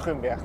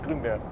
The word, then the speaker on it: Grunberg: Ik, zeg altijd in Nederland Grunberg, want we zijn niet hier in het Duitsstalig gebied. Dus we gaan niet op zijn Duits uitspreken. Dus is Grunberg.